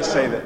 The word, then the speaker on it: salmon: But see, when we see all these things put in place, like the laws of nature, like the salmon, I can't ever say that right. What are the fish that swim upstream? Somebody salmon. – Say that. – Salmon.